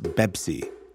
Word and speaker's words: Pepsi: In Arabic, there is no letter like P, but there is B. That is why Pepsi is pronounced Bebsi in the Arab world.